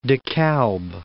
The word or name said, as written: De Kalb